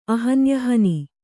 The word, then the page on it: ಅಹನ್ಯಹನಿ: ahanyahani